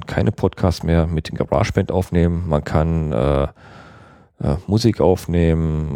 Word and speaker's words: GarageBand: GarageBand oder wie auch immer man manche das aussprechen wollen. Ich spreche es GarageBand aus, das Programm von Apple, was bei jedem Mac somit beigeliefert wird.